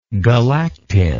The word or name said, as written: galactin